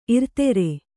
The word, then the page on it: ಇರ್ತರ: irtara